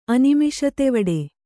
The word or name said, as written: ಅನಿಮಿಷತೆವಡೆ